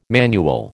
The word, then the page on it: manual: American English